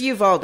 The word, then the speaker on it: Uvalde: It's Uvalde.